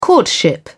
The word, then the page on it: courtship: British English